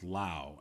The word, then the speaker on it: Lowe: they got Eric Sogard to kind of bridge the gap between, like, if Brandon Lau, I think his is pronounced Lau, and mm-hmm.